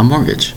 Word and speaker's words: mortgage: It's called a mortgage, spelled M-O-R-T-G-A-G-E. It's called a mortgage. Notice again the T is in the spelling of the word mortgage. The T remains totally silent in their pronunciation. The word is mortgage, M-O-R-T-G-A-G-E.